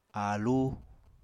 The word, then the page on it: aalú: Apatani